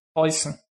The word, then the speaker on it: Preußen: Preußen.